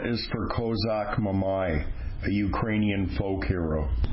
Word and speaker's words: Kozak: K is for Kozak, the proper pronunciation of what non-Ukrainians call Cossack. From a Turkish word for free man or outlaw, the original Ukrainian freedom fighters.